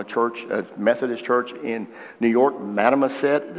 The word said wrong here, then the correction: Mamaroneck: Did I say it right? – Mamaroneck. – Mamaroneck. Okay.